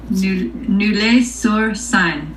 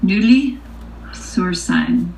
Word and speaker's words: Neuilly-sur-Seine: Nulle sur scène. And that's in France. Nulle sur scène.